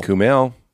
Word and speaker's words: Kumail: Kumail. Yeah. He said when I met him, he goes like email.